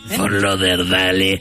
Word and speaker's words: Fort Lauderdale: Fort Lauderdale.